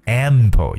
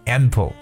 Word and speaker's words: ample: 千万不要觉得我说的是苹果，苹果叫 apple，but this is ample，有一个鼻音 m 的发音。 ample, right? A M P L E. The word ample means enough or more than enough，表示足够的，或者说表示丰裕的，也就真的是大量的说法。